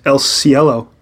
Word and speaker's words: El Cielo: El Cielo. Am I saying it right now? – Yes. Well done.